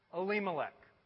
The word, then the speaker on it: Elimelech: It's pronounced Elimelech.